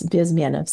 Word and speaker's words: Bezmenov: It's supposed to be, people say Bezmenov, it's Bezmenov. So I have to be a geek and say it the right way in Russian.